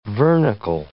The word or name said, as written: vernacle